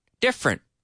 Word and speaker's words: different: Different, different, right? I have a different microphone today than I had yesterday. The microphone I'm using today is different. The headphones I'm wearing are different. Different. Different. Two syllables.